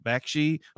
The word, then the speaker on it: Bakshi: Bakshi.